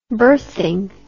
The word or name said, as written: berthing